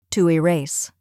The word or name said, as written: to erase